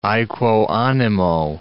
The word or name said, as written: aequo animo